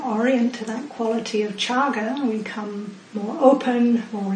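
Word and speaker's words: cāga: So he used a different word for this, and that word is the Pali word chaga, spelled C-A-G-A, and C is pronounced as C-H in Pali.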